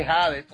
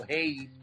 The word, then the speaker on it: Hades: la historia de Hades, o Hades, como usted le quiera pronunciar, que está en Netflix.